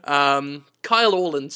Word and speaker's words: Kyle Orland: I mean, that's the worst part of this, is, I was speaking with Kyle Orr, totally forgot how to say his name. Sorry, it's early in the morning. Kyle Orland, sorry. I don't know why I called him Kyle Orl.